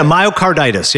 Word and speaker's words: myocarditis: I mean, I think that, you know, this whole case that they built, the canceling of the Big Ten around on the myocarditis, is that how you say it? – Yeah, myocarditis, yep.